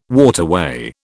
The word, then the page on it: waterway: British English